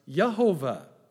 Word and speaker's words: Yehovah: There's a problem with that because there's not a J sound in the Hebrew language. At best, his name would be pronounced Yehovah.